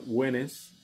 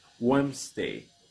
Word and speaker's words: Wednesday: Muchas personas lo pronuncian Wednesday y es Wednesday. Esa es la pronunciación, Wednesday.